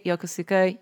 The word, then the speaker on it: Yokosuka: And I was like, I think if you say Yokosuka, you should be fine. Mm-hmm. Because Yokosuka, like it would be like, and the Pokemon Go event is taking place in Yokosuka.